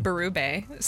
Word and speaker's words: Berube: The French Bet pronunciation. – Berube. So there is some people who still say Berube. – I know, they're wrong.